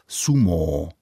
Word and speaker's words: sumo: Z Japonska se do světa šířily i některé specifické sporty, například sumo. Schválně vyslovuji to dlouhé O na konci, které tam je i v japonské výslovnosti.